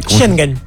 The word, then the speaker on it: Schengen: Consultado por la reacción del ejecutivo italiano que pidió el cierre del espacio Schengen con España. – Schengen. – ¿Cómo se dice? – Schengen. – Schengen. – Schengen.